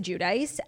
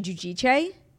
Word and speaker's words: Giudice: Yeah, I mean, forever it was Teresa Giudice. And then like years and years later, it came out that like, we were all saying it wrong. It's actually Giudice.